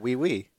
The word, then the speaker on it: oui: Is that how O-U-I, is that we? – Yeah, we.